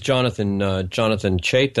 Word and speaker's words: Jonathan Chait: That was Jonathan Chait.